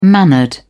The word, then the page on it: mannered: British English